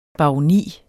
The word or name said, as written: baroni